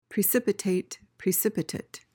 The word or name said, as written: precipitate